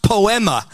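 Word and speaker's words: poema (ποίημα): It's pronounced poema, which where we get our English word poem.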